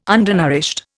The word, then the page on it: undernourished: Indian